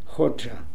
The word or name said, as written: Hodja